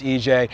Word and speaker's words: Ije: Yeah, so EJ, or IJE, but pronounced EJ, is actually a Nigerian word, which means movement.